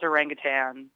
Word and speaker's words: orangutan: A lot of people do pronounce it orangutan, like, but the actual spelling of it, there's no G at the end of the word.